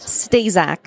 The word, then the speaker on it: Staszak: Stazak.